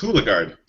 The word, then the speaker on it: Hulegaard: I should have asked you this beforehand. Hulegaard? – It's Hulegaard.